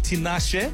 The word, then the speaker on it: Tinashe: It's Mid-Mornings with Mo Flavor on 947 Disco Lines with Tinashe. Well, at least that's how we pronounce it in Southern Africa. But the Americans would probably be happier with Tinashe.